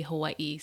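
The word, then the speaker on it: Hawaii (Hawaiʻi): The Okina happens between two vowel sounds. People say Hawaii, but it's really Hawaii, so thank you.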